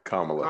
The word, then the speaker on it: Kamala: If Camilla, what's how you say it? Camilla? – Kamala. – Kamala.